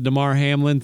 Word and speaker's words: Damar Hamlin: I'm not on the conspiracy train that because of the Damar Hamlin thing. I'm sorry. Did I say his name right? – You did.